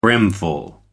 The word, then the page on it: brimful: American English